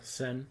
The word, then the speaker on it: Seine: What is it pronounced? Seine? – Seine. – The Seine. Mm-hmm.